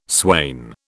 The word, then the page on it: swain: British English